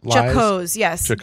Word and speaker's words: jocose: I don't know how you pronounce this word. Jocose? – Jocose, yes. J-O-C-O-S-E.